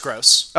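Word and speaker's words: Groß: I think it's Grobe. I think I got autocorrected. – No, it's Gross. – Oh, it is? Okay. – It's like spelled Grobe, but it's pronounced Gross.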